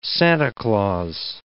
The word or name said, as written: Santa Claus